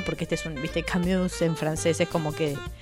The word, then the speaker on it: Camus: ¿Cómo se dice? Camus.